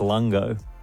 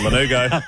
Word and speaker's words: lanugo: Sorry, mate. It's Lanugo. It's Lanugo.